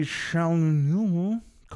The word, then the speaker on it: Chalamet: We have, it looks like Timothy Chalamet. Call Me by Your Name. That's the way he likes it pronounced.